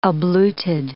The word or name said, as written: abluted